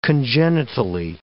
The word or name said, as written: congenitally